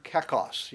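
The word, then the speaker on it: kakos (κακός): It is the Greek word kakos. You'd probably spell it, if you were to spell it phonetically, K-O-K-O-S, kakos.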